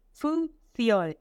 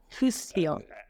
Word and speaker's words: Fuziol: My last name is Fuziol. It's a French name. I cannot even pronounce it well, but it will be Fuziol.